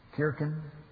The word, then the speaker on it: Kirche: That became the German word for church, Kirche, Kirchen. Scandinavian, Kirku, Finnish, Kirk, Scottish, and English, church. You used to have a hard C sound when you pronounced it, Kirche.